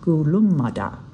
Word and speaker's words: Gulumada: But the original one, the one our Gundungura guide, David King, uses, is Kolomata, pronounced Gulumada. The area was declared a national park in 1959, a decision King credits with helping to preserve his people's culture and lore.